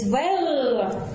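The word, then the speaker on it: vert: Зеленый будет вер, зеленая будет, соответственно, верт.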